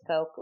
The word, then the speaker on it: Koch: but it's Koch, like the soda.